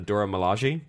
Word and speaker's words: Dora Milaje: Like I think I figured out how to say the Dora's full name. It's the Dora Milaje.